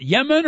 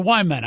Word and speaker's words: Yemen: And first of all, is it Yemen or Wyman? I always thought it was Wyman. – It's Yemen, Coach. – Really? – Yeah, just say it like if you're Jamaican, Yemen. – Okay.